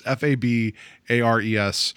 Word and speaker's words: Fabares: I looked up how to pronounce her last name. Farbara. I'm going to mess it up. It's Fabares.